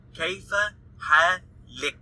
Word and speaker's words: kayfa halik: If I'm speaking to a female, I say kayfa halik. So, halak is for male, halik is for female. So, kayfa halak, I'm speaking to a guy. Kayfa halik, I'm speaking to a woman.